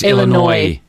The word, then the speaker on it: Illinois: No S. – Oh, it's just Illinois. Oh. – Don't pronounce the S.